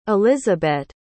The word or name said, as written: elizabet